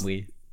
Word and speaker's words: ennui: Ennui.